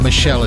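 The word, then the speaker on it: Meshell: the artist's name is Michelle Enganchello. I knew I'd get that right. Michelle. Just got that right, but it's not spelt in the normal way that Michelle is spelt. It's M-E-S-H-E-L-L, along with Leanne LaHabas.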